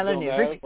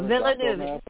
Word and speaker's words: Villeneuve: It's not Villeneuve, like former IndyCar. – No, Villeneuve. Think French Canadian. Think French Canadian, which is what he is.